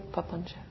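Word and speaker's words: papañca: You know this word, papancha? This is the word the Buddha taught for the proliferation of mind, which is what I'm talking about. P-A-P-A, like papa, N-C-A, papancha, C is pronounced cha, papancha, proliferation.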